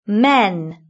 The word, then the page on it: men: British English